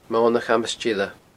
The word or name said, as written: Monadh Chamas Teile